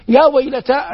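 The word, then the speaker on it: يا ويلتى: يا ويلتا، الألف في يا ويلتا قال بعضهم إنها للاستغاثة، وهي مكتوبة في المصاحف التي بين أيدينا على هيئة الياء وإن كانت تنطق ألفا.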